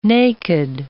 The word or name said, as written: naked